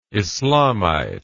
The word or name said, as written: islamite